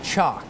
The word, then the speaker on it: chalk: So the word you spelled, shock, is pronounced very similar to the word chalk. It's important to listen between ch and sh.